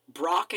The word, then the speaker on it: Brokkr: And they're like, "So Brock is spelled B R O K K R. Um, Etri is E I T R I." and I'm really just shooting in the dark with these definite with the pronunciations. Uh, Brock is Brock is definitely Brock. It's spelled weird, but it's definitely Brock.